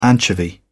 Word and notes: anchovy: British English

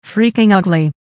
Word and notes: freaking ugly: American English

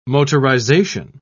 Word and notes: motorization: British English

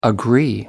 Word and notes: agree: American English

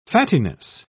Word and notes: fattiness: British English

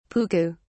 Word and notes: puku: British English

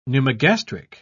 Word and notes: pneumogastric: British English

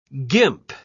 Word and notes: gimp: British English

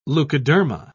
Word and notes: leukoderma: British English

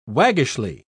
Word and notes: waggishly: British English

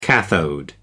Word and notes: cathode: American English

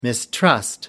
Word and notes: mistrust: American English

